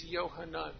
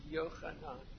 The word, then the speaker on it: Yohanan: By the way, you read it as Yohanan, but it would be pronounced Yohanan. That is his name. I'm not just adding guttural for the fun of it.